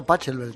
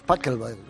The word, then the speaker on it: Pachelbel: No, es que yo decía Pachelbel también. – Sí, pues. – Y luego me dijeron, no, es Pachelbel.